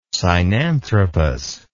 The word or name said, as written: sinanthropus